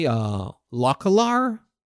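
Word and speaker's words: Lokholar: Yeah, and you play Lokalar. Is that how you pronounce it? The Ice Lord? – Yes, the 8-8 Rush Wind Fury.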